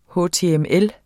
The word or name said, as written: html